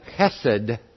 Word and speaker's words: chesed: The steadfast love or the chesed, that's how you say it in Hebrew.